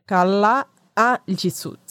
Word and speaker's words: Kalaallisut: O mesmo nome da língua é Kalaallisut Escreve com dois As depois do.